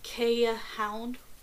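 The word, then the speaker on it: Keeshond: The name of these energetic Dutch barge dogs is pronounced Kea Hound with the plural from Quiche Hound.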